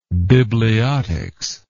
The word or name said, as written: bibliotist